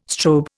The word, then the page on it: strobe: Indian